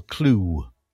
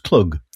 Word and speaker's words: Klugh: And before that, Dance With Me, performed by Earl Clue, which is a strange name, spelt Clug. Perhaps he should be pronounced Clug. K-L-U-G-H. Yeah, very nice.